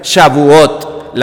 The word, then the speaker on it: Shavuot: Y en hebreo, Pentecostés, no se dice Pentecostés, eh, se dice la fiesta de las semanas y eso se pronuncia Shavuot, la fiesta de las semanas.